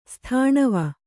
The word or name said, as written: ಸ್ಥಾಣವ